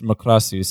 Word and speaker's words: Macross: So it's Makrasu. So it's like the way that someone who speaks Japanese would say it in like katakana, where they have to like romanizely pronounce it with their own characters.